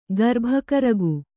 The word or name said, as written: ಗರ್ಭಕರಗು